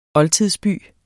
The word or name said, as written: oldtidsby